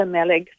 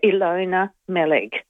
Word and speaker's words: Meleg: Yes, I know the Meleg family. So her name Meleg. Is pronounced, yeah, Ilona Meleg.